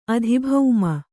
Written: ಅಧಿಭೌಮ

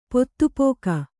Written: ಪೊತ್ತುಪೋಕ

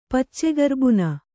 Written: ಪಚ್ಚೆಗರ್ಬುನ

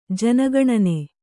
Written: ಜನಗಣನೆ

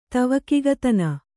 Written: ತವಕಿಗತನ

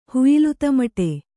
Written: ಹುಯಿಲುತಮಟೆ

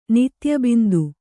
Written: ನಿತ್ಯಬಿಂದು

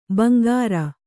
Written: ಬಂಗಾರ

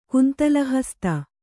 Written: ಕುಂತಲಹಸ್ತ

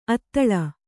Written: ಅದಳ

